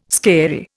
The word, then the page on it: scary: Indian